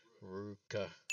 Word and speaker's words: Ruka: So, you know, the correct pronunciation apparently is Ruka. – Ruka. – Ruka? – Ruka. – Ruka? – Mm-hmm. We were told it's pronounced like Ruby and Aka. Ruka.